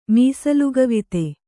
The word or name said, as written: ಮೀಸಲುಗವಿತೆ